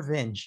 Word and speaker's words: Vinge: How do you pronounce his name? Vinge? V-I-N-G? Vinge. – Werner Vinge, yeah.